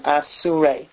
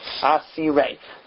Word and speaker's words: Asirei: Grammatically, the right way to write it would have been with a Vav, the way it's actually written, Asurei. That's grammatically the correct way to say it. Those who are prisoners of the king, Asurei HaMelech Asurim. That would have been the correct grammatical way to say it. But the Torah changes the Vav to a Yud in the way that you read it. Asirei.